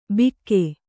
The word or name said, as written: ಬಿಕ್ಕೆ